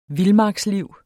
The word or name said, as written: vildmarksliv